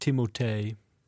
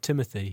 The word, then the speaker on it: Timothée: It's pronounced Timothée. Yeah, he's not Timothy. He's Timothée.